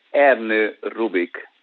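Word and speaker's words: Ernő Rubik: Let me just specify it, Erno Rubik, to be more precise with the name of the gentleman.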